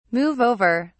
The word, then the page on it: move over: British English